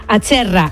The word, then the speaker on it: Acerra: A ver si lo digo bien, ¿es Acerra o Acherra? – Acherra.